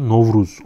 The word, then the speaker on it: Novruz: Mart Nevruz oluyor. Novruz onların ta, e, telaffuzuyla.